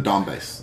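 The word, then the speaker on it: Donbas: Eastern, I believe is it the Dubas region? – The Donbass.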